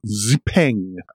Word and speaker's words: Xpeng: The 2024 Xpeng, I think that's how you pronounce it. Possibly not with quite as much emphasis. It's not Xpeng, but Xpeng.